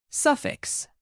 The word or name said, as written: suffix